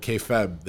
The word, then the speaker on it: kayfabe: You know, way back when they hit it, they had this word, kayfabe, that was, am I saying it wrong? – Kayfabe. – Kayfabe, yeah.